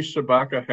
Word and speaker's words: Shabaka: What is the, I might be getting his name wrong. Barry Shabatka Henley? – Yes, that's a good scene. Yeah, Barry Shabaka Henley.